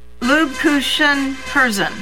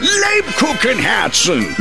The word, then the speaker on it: Lebkuchenherzen: Lubkuchen Herzen. – The Kupenhergens Herzen. – Lubkuchen Herzen. – Nine! Lebkuchenherzen!